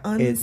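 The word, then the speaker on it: Unsullied: The unisold? The unsold? – You're saying it wrong. – Unsold.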